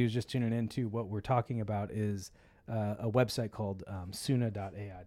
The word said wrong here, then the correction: Suno: Did I say it right? Suna? I just closed it. There we go. Oh, sorry. Suno. Suno.ai.